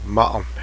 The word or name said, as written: ice